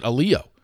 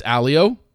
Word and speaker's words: Allio: I think all of us, for whatever reason, have always just pronounced it Alio. It's Alio. He made that clear, and he said everyone's been pronouncing it wrong.